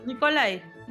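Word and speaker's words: Nikolaj: Danesa, la dirige Nicolás Arcel, pero ponerle que se pronuncia así. Nicolai, Nicolai, sí, Arcel, no sé.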